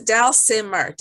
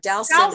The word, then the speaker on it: Dalsimer: It's Dalsimer. Dalsimer. Dalsimer.